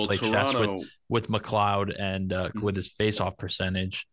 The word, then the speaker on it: McLeod: I always hear different pronunciations of his last name. – McLeod. – McLeod. Yeah. Okay. That's what I figured.